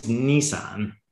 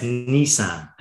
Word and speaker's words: Nissan: I joined Nissan, as it's pronounced. I used to call it. – Is it? Okay. Because I said Nissan. I've said it wrong then. So first faux pas. So Nissan. Okay. – Yeah, it's Nissan.